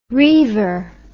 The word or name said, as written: riever